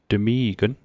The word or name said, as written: tmi'gn